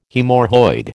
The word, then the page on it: haemorrhoid: American English